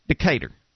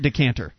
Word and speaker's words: Decatur: You got to get the correct Southern pronunciation. It's Decatur. Not Decatur. – I thought the Decatur was in Illinois. – A decanter is something you drink from, I believe. Decatur. Decatur, Alabama.